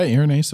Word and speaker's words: Irenaeus: Saint Irenaeus. Did I say that right? – Yeah, Irenaeus of Lyon, whose feast day we just celebrated.